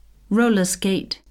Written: roller-skate

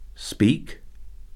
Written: speak

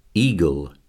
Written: eagle

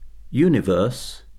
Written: universe

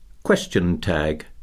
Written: question tag

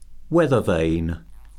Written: weathervane

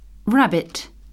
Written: rabbit

